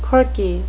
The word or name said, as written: corky